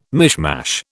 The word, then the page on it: mishmash: British English